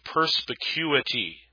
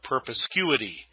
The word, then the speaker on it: perspicuity: This is called the doctrine of perspicuity. Perspicuity. Sometimes you'll hear people mispronounce that word and call it perspicuity. but it's perspicuity.